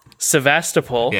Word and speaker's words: Sevastopol: When the crew arrive at Sevastopol, how do you say that? – Sevastopol.